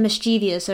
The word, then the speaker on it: mischievous: But why the weird thing is it's mischievous, like there's an I, it's like it's I-O-U-S.